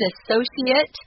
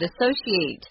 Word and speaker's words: associate: Yeah, so the noun has kind of an I sound, associate, and the verb has an A sound, associate. So they are different.